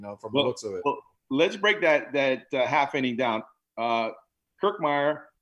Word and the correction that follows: Kiermaier: He had a Kiermaier. – How do you say it? – Kiermaier. – Kiermaier. Okay.